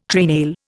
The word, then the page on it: treenail: Indian